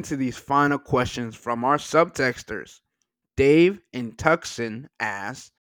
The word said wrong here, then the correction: Tucson: Did you just say Tuxin? – Yeah, what? It's T-U-C-S-O-A-N. – It's Tucson. Tucson, Arizona.